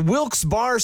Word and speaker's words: Wilkes-Barre: And you're listening to Wilkes Barre Scranton's 97.9X.